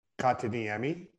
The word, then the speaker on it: Kotkaniemi: Kotkaniemi.